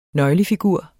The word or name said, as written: nøglefigur